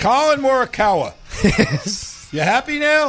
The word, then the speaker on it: Morikawa: I said Colin Marikawa. Okay? – But his name's Colin. – Colin Marikawa. – No, Morikawa. It's Colin Morikawa.